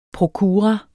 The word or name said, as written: prokura